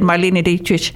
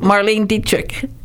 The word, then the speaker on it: Marlene Dietrich: But there's really very little when, I know I pronounced it, I think, the European way. I said Marlene Dietrich. But it's, of course, sort of Americans think Marlene Dietrich.